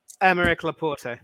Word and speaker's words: Aymeric Laporte: Um, Aymeric Laporte.